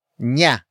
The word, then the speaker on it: niya: The answer is introduced by the next word, nya, which is spelled as N-I-Y-A.